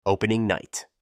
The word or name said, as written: Opening night